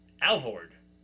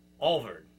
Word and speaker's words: Alvord: That word is a person's last name. Alvord. That is the correct way to say it. Alvord. But you'll hear me say it 18 times as Alvord.